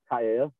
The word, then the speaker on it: Kaiea: So my name is Kaya, and I go by I Am Kaya. And it's spelled K-A-I-E-A.